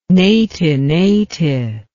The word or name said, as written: neti neti